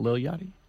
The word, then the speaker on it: Lil Yachty: Lil Yachty, not Lil Yachty? – Lil Yachty. My bad. Mispronunciation.